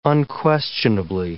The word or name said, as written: unquestionably